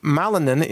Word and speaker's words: Malinen: M.T. Malinen, M-A-L-I-N-E-N, or you could think of it as Ma-Linen. That's the way to spell it.